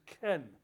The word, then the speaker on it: chen: The Hebrew word there is chen, C-H-E-N is how we'd spell it with English characters.